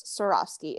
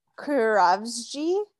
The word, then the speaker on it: Swarovski: Pronounce Swarovski. And she goes, Kurovsky. And they're like, no. They're like, you tried. Like, they don't even laugh at her attempt to effort. They're like, that's not it.